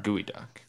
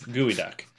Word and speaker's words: Geoduck: And then Geoducks are... – Gooey duck. – Or sorry, yeah, Geoduck. – It's spelled like Geoduck. – It is. Gooey duck.